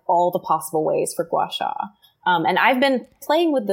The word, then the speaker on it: gua sha: She pronounces it gua sha. She doesn't pronounce the H in it.